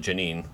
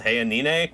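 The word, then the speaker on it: Jeanine: The military ousted Morales and a new leader, Janina Nez, which actually she does pronounce it Janine, which is really weird. I thought it was Hayanine, but nope, just straight up Janina Nez.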